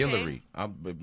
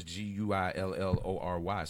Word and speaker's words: Guillory: Guillory. My last name is Guillory, spelled like guillotine. We French for real. I'm Gerard Guillory.